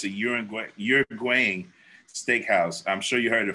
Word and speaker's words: Uruguayan: Uruguayan steakhouse. Uruguayan. Uh-huh. Uruguayan. That's good.